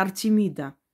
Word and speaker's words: Артемида: Это Артемида.